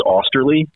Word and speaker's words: Austerly: So, you know, my last name is Austerly. – All right. So I did say it right. – Yeah. I always go like phonetically when I'm going to a conference, like AWE-STIR-LEE, you know, like it's pretty easy when you see it that way.